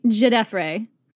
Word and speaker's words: Djedefre: It is fun to say, Djedefre. – I really struggle saying it because it's not the way it's spelled, and that really throws me. – Djedefre. – There's a D at the front. – A silent D, correct.